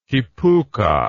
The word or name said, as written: kipuka